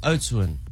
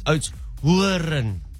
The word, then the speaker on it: Oudtshoorn: And they said, please pronounce it correctly. – Oh. – What have you been saying and what do they want you to say? – Well, I've been saying Oudtshoorn. They said it's Oudtshoorn.